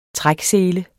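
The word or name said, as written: træksele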